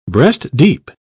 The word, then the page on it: breast-deep: British English